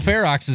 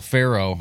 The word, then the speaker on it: Ferox: Cannibal Ferox is cool though. For years I thought it was Cannibal Pharaoh. I was saying it wrong for so many years.